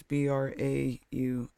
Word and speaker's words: Braun: And it's Braun. – UN. – Because it's German!